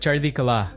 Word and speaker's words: Chardikala: Can you correct me on that pronunciation? – Yeah, Sonny? You're pretty good. Chardikala.